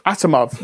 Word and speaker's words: Asimov: Well, it's based on the classic sci-fi novel by Isaac Asimov. I'm sorry if I'm pronouncing that incorrectly. – Asimov, yes, thank you.